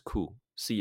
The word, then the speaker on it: coup: 那最后一个是俄罗斯境内的可以发生的事情是什么？A palace coup，C O U P 啊，这个 P 不用发音，A palace coup 就是说，因为。